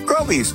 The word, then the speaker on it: Groby's: Groby's, where the accent is on grow.